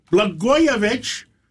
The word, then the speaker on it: Blagojevich: And we have former governor Rod Blagojevich. I said it right.